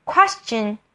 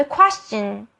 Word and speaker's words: question: Question，其实呢，question 是一般的一开始的发音。 Question，this question。 Q-U-E-S，quest，然后再 chen，C-H-ch，question，question，可是在这边它的 C-H 后面的音发得非常的小声，就变成 the question，大家可以试试看。